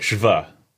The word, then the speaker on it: Schwalbe: In some podcast or YouTube video, we said we weren't exactly sure how to pronounce that word, and a German guy sent us a voice memo on Instagram telling us... Yeah, it was like Schwable. Yeah. – No, it's like Schwa. – Schwa. – The S-C-H-V-W that makes like a V sound.